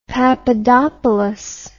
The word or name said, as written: papadopoulos